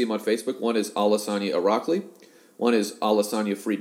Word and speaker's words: Alasania: And Irakli is kind of as it sounds, I-R-A-K-L-I, Alasania is A-L-A-S-A-N-I-A. So it's kind of the simpler spelling. Don't complicate it with additional S's and other silent letters.